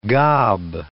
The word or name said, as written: gob